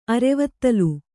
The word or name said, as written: ಅರೆವತ್ತಲು